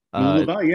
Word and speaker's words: Mooloolaba: Mooloolaba, yeah, that's right.